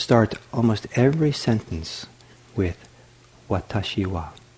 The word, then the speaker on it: watashi: So the word for I is three syllables long. It's long, like watashi.